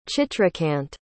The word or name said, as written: chitrakant